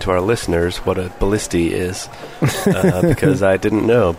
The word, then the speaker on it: ballistae: The plural is pronounced ballistae.